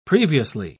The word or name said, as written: previously